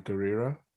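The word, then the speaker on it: Gurira: D-A-N-A-I. Please. I hope I'm pronouncing her name right. – I believe it's Denai Garrera. – Garrera, okay. – D-N-A Garrera.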